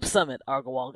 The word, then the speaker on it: Sumit Agarwal: I'm Sumit Agarwal, guys.